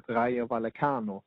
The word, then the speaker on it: Rayo Vallecano: Levante 20 plats, Getafe 19 plats och plats 18 som du fick lära mig att uttala på rätt sätt, Rayo Vallecano på...